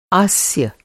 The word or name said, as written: आस्य